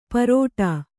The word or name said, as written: ಪರೋಟಾ